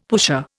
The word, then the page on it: pusher: Indian